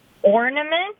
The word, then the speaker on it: ornament: Ornament? I usually put a D in it, so it's ornament. – Yeah, there's no D in there.